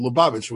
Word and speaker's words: Lubavitch: So we pronounce it Luban, or we pronounce it Lubavitch with an L.